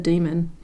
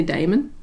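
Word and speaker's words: daemon: Lyra lives in a world where human souls take a physical form as a demon. I always used to say Damon. – Me too. – Because it looks like it's spelled.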